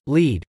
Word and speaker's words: Lead: We lived in the small town of Lead, SD. Pronounced, Lead, which is about 2 miles further up the hill from Deadwood SD.